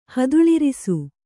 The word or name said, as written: ಹದುಳಿರಿಸು